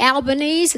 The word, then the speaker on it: Albanese: I want to also especially thank Anthony Albanese.